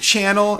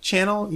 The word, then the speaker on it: Chanel: I need some Gucci and some Chanel, and I'm like, Chanel? You mean Chanel? If you can't pronounce it right, you probably don't need to have it.